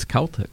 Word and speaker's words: Celtic: So you can finally answer the question, Celtic or Celtic? It's Celtic. – Celtic is the accepted pronunciation. Celtic is a soccer team in Scotland.